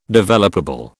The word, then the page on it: developable: British English